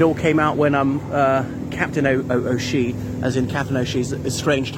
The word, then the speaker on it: O'Shea: Professor Roy Foster hates when people pronounce Shea, says it's actually O'Shea, and don't call her Kitty.